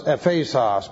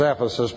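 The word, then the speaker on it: ephesos (Ἔφεσος): It's ephesos. But if I start talking about the church of ephesos, you would never know where ephesos was, and you'd wonder why I can't pronounce words such as Ephesus properly.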